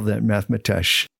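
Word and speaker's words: Matemátx: It looks like you would print, it's like mathematics, except replace the I-C-S with an X. – And you pronounce it mathematesh. – Mathematesh, because the X is pronounced sh, because that's to honor Nahuatl, the language, the Mayan language.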